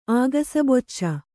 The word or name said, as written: ಆಗಸಬೊಚ್ಚ